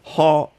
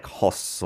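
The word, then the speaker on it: 핫: So, hot and then a shiot pachim there, so it's not like hoss or something like that. Just hot.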